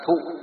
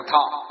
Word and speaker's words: thọ: 5 món, thứ nhất là xúc, thứ hai là tác ý, thứ ba là thọ, hay còn gọi là thụ. Miền Bắc chúng ta đọc là thụ, miền Nam đọc là thọ.